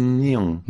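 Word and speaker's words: Nihon (日本): On dit Nihon. – Nihon. N-I-H-O-N, ça s'écrit, c'est deux mots, Nihon.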